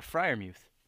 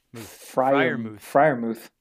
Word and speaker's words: Freiermuth: Yeah, Freiermuth. – Freiermuth.